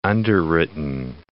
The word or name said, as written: underwritten